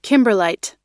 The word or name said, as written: kimberlite